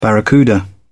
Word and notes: barracuda: British English

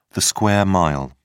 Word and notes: square mile: British English

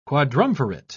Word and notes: quadrumvirate: British English

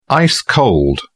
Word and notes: ice-cold: British English

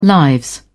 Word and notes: lives: British English

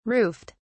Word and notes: roofed: British English

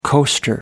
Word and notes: coaster: American English

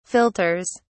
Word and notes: filters: British English

